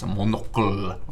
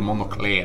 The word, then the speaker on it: monocle: Ordet kommer från senlatinets monoculus, alltså enögd, via franskans monocle, eller monocle någonting. Jag kan inte franska uttalet. Monocle. – Monocle, ja.